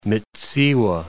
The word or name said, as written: Mits'iwa